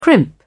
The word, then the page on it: crimp: British English